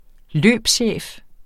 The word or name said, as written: løbschef